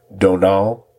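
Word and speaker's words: Domhnall: I looked up how to pronounce his name, and that's kind of sort of how it's pronounced. I probably still screwed it up. It's very similar to saying Donald, but no, it's not spelled that way.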